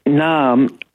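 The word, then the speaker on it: Naarm: That's a good point, because if you took out the first N and the A, you'd have arm, which we pronounce the same way. So they could have just had one A and it'd still be Naam, wouldn't it? – Exactly.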